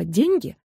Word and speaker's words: деньги: При склонении существительного «деньги» ударение на первый слог считается устаревшим.